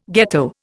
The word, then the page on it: ghetto: Indian